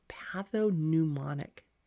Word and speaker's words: pathognomonic: So I pronounce this one as, the world pronounces this one as pathognomonic changes.